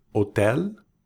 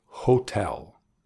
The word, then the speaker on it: hotel: El inglés para decir hotel, ks. That's right, hotel, with the emphasis on the second syllable, hotel.